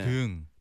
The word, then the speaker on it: 등: 왜냐면 등이라는 게 한국어로 이제 그 장음으로 발음을 해요. – 네. 네. – 등.